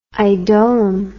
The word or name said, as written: idolum